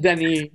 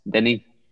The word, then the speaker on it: Denis: I mean, it's just Denis. You just don't say the S. – Denis.